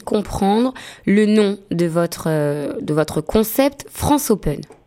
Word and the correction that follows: Franc'Open Mic: Alors Franco Open Mic. – D'accord, donc déjà je le prononce mal.